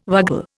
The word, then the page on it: vagal: Indian